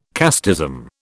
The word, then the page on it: casteism: British English